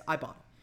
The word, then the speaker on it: Ibotta: I forget how they want us to pronounce it, but it's Ibotta. That's Ibotta. You can earn cash back on every shopping trip.